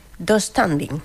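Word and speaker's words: döstädning: Yo lo pronuncio... Do standing. – Sí, así. Tengo esa silla de IKEA. Sí, se pronuncia así.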